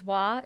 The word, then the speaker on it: Wah: My name is Wah. It's pronounced just like water, but without the ter.